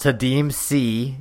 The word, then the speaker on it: Kadeem Sy: I believe his name is pronounced Kadeem C. It is spelled Kadeem Sy.